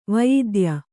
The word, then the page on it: ವಯಿದ್ಯ: Kannada